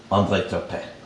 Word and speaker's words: André Turpin: We are honored today to have with us one of Canada's most unique and tremendous cinematic talents, André Turpin. Did I say that right? – That was perfect.